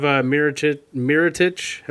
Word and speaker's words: Mirotić: And then you have Mirotić, am I pronouncing that right, from Chicago? – You are, yeah.